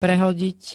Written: prehodiť

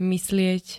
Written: myslieť

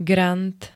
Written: grant

